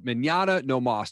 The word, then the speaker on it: Mañana No Mas: I always love talking with other podcasters like yourself, and your podcast is called Mañana No Mas. Did I say that right? – You did. Yeah.